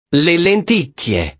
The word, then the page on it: le lenticchie: italiano